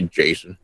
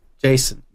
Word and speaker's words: Jaycen: Oh, well, his name is going to be Jason. – Jason. No, but it's spelled J-A-Y-C-E-N. – Oh, there you go. – Yeah, yeah. It's spelled different.